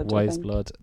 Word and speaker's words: Wise Blood: It has a buzz of kind of a couple of albums that I would have been into more recently, like Why Is Blood, or how do you say it? Why Is Blood? – Wise Blood.